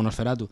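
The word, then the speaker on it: NOS4A2: La portada es, es una matrícula de un coche, que es NOS 4A2, y la pronunciación suena como Nosferatu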